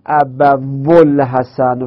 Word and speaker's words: ابوالحسن: آیا میگم جا ابول حسن واو رو تلفظ میکنم؟ نه، واو در تلفظ من میفته، میشه ابول حسن، چنده این واو بیچاره نیست، ابول حسن.